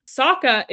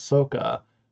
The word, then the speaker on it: Sokka: And then my final fun fact for us is in the dialogue between Han and Sokka, Han pronounces Sokka's name incorrectly by elongating the O, which is the Asian pronunciation and how it is pronounced in the film The Last Airbender.